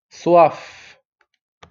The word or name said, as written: soif